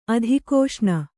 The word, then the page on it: ಅಧೀಕ್ಷಣ: adhīkṣaṇa